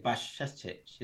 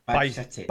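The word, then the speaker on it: Bajčetić: Bajcetic? Is that how you pronounce it? Sorry? – Yeah. Well done. – Bajcetic.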